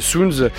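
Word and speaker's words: Suuns: Soons qu'il faut écrire comme soleil au pluriel en anglais avec double U, et qu'on prononce donc Soons comme le chiffre zéro en thaï.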